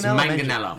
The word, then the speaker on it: Manganiello: It's Manganiello.